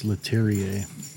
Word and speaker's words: Leterrier: I would say it's Leterrier. – That's probably why. It's French.